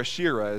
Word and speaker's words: Asherah: In this case, it was the partner to Baal, and it was dedicated to the goddess Asherah, or Asherah as it's sometimes pronounced.